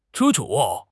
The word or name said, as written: 盛情